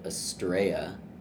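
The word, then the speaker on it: Astraea: He plays bass in the band Astrea. Pronounce that right? – You did. You nailed it.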